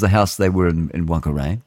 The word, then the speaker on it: Whangarei: When the girl and her family, they've said whānau, moved to Whangarei, they probably pronounce that Whangarei, but it's incorrect. It's an aspirated WH, and just the Maoris want to say fuck, I suppose.